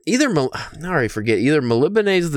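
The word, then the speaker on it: Melniboné: Well, I, like the Melibane, which is the city, I learned how to pronounce that, which I mean, it's kind, you know, it could be multiple things, but it's, you get close to the pronunciation by reading it, like phonetically, but I just looked up a couple YouTube videos and, you know, Elric of Melibane.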